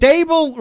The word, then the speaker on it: Dable: So, is it Dable rhymes with table? – Yes. That's what he said.